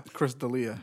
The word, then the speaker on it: D'Elia: Dahlia? – Chris D'Elia.